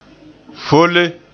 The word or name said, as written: folle